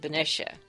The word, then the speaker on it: Benicia: That's how you can tell the old-timers from the newcomers. – And that is? – Is how we say it. Now I pronounce it Benicia.